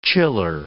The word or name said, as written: chiller